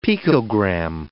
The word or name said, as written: picogram